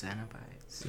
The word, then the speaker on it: Cenobites: Cenobites or Xenobites? – It's Xenobites.